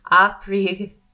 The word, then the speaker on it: Aparigraha: And then from there, our fifth one, and then we'll get into our silence, this aprigaraha. Oh, I always say it wrong. Aprigraha.